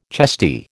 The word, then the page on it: chesty: American English